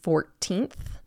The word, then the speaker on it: 14th: We have Valentine's Day, or the Day of Love, and that's celebrated on February 14th. If you're pronouncing these, remember the th. Don't forget the th at the end, 14th.